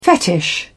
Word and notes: fetish: British English

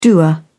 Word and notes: doer: British English

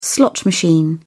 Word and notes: slot machine: British English

